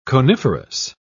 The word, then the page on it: coniferous: British English